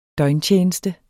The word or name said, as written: døgntjeneste